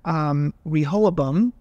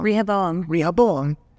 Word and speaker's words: Rehoboam: We find Rehoboam. Still saying it wrong. – Rehoboam.